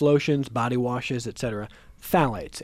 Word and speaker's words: phthalates: Phthalates, P-H-T-H-A-L-A-T-E-S. Dr. Delahanty, you're smiling at me. Am I pronouncing it correctly? Are we spelling it? – I think the PH is silent, so I think it's just phthalates.